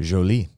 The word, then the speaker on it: Jolie: Did I say that properly? – Yes, you did. – Jolie.